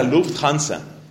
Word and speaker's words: Lufthansa: Nosotros, pues obviamente aquí pronunciamos la Lufthansa. Pero propiamente es la Lufthansa.